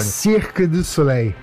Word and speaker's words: Cirque du Soleil: Por favor, qual que é a pronúncia correta em francês, você que esteve lá durante muito tempo? Fala pertinho do microfone. – Cirque du Soleil.